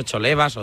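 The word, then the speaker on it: Cholevas: Entonces, claro, había que ver si era Jolebas o Cholebas o tal. Entonces yo le dije que en Forbo decían Jolebas, pero Sisto Miguel Serrano, que es una institución, decía que no, que se pronunciaba de la otra manera.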